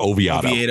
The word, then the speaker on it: Oviedo: Oviedo or Oviedo? – Oviedo.